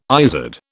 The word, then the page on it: izard: British English